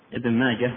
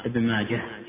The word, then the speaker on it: ابن ماجه: عندنا هنا نقط ابن ماجة ونونه، والصواب في ضبطه أنه ينطقها ابن ماجة هكذا ولا يقال ابن ماجة يعني في حال الوصل، هذا هو الأصوب مع العلم أن من جعله شاء فإن له وجه.